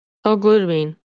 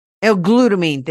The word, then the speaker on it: L-glutamine: How do you say that word? – L-glutamine. – L-glutamine, thank you.